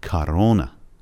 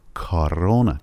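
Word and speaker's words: کارونا (корона): شاید تعجب بکنید که من چرا کارونا تلفظ کردم، در حالی که بعد از حرف کا توی تصویر او میبینید. یه او داریم که صدای آ تلفظ شده و یه او دیگه داریم، دومین او، که صدای او تلفظ شده. علتش هم این هستش که استرس اودارنیه یا فشار روی حرف روی او دوم قرار داره.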